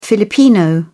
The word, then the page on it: filipino: British English